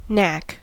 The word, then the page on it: Gabe: /ˈɡaːbə/